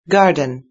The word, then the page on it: garden: British English